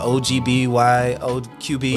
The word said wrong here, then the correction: OB-GYN: O-G-B-Y-N? – Yeah. – Yeah. – Sorry, what did you call it? – O-B-G-Y-N.